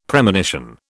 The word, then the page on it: Premonition: British English